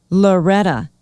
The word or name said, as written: loreta